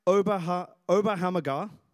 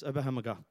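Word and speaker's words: Oberammergau: For nearly 400 years, the people of, I'm going to pronounce this right, Oberhammergau. Yes, Oberhammergau.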